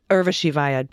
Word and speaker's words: Urvashi Vaid: Urvashi Vaid.